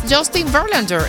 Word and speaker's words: Justin Verlander: Mira, es Justin Verlander. ¿Es que se pronuncia? – Así, perfectamente, ¿sí? – Así.